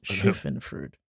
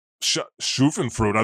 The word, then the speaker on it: Schadenfreude: It's called Schadenfreude. – Schadenfreude. I thought it was Schadenfreude. – you know what? Let's go with yours. Let's go with your pronunciation.